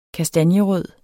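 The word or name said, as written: kastanjerød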